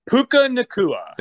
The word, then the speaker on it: Puka Nacua: Well, how do you say his name? You're a fantasy guy. – Puka Nacua. – Thank you.